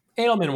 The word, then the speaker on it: Edelman: Or Edelman? How do we say that? – Edelman works.